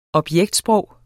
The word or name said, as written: objektsprog